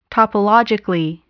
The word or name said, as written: topologically